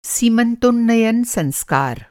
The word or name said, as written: सीमन्तोन्नयन संस्कार